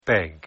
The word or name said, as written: beg